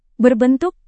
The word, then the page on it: berbentuk: indonesian